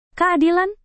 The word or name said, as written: keadilan